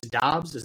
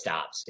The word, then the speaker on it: Doubs: I always get mixed up if it's Doubs or Dubs. I think it's Doubs. Is that right? – I think it's Doubs, yeah.